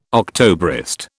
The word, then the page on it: Octobrist: British English